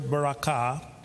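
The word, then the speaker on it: barakah (ברכה): So we said it's the word blessing is the word Hebrew word barakah. The emphasis is on the last syllable.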